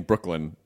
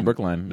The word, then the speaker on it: Brookline: I always feel like that's a that's an arrogant person saying Brooklyn, but Brookline. – No, Brookline. Yeah. It's spelled L-I-N-E.